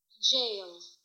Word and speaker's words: gaol: Jail. No, it's pronounced jail. All right. Jail. That's what I thought. But you know, it's so peculiar looking here in English that you think, oh, that can't be how it's pronounced, but in fact it is.